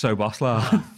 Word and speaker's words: Szoboszlai: Now, everyone's still like debating how to actually say his name, even though he confirmed it and everyone just kind of went, we just call him Szobos. He's just Szoboszlai.